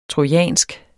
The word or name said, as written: trojansk